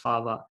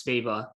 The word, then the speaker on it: Sveva: And I just want to say real quick everyone says Sofie Svava. And I thought that was how you pronounce it initially. And when I say everyone, the commentators, everyone says Svava. I'm just letting everyone know PSA, it's Sveva. And I know this because I found a video of her saying her name herself.